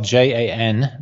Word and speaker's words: Jan Dawson: Yeah, so my name is spelled JANDAWSON, and that's my Twitter handle as well.